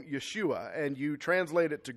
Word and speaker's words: Yeshua: In actuality, Jesus' name in Hebrew, if you were to pronounce it, is Yeshua, which sounds less like Jesus and more like Joshua, and that's because it is.